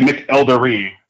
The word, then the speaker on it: McElderry: It's actually McElderry. It's a D.